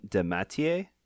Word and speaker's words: DeMatteis: How did you pronounce it? – J.M. DeMatteis? – Yeah, I think you're right. No, I was going to correct you, but you're right.